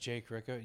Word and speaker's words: Jake Ricca: It's my name, just Jake Ricca.